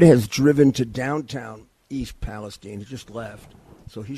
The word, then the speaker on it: Palestine: This is Rudy Giuliani, and I am talking to you from Canfield, Ohio, which is 15 minutes from East Palestine, Palestine is the right pronunciation here among the people here, Ohio.